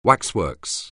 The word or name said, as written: waxworks